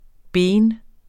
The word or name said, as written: BENS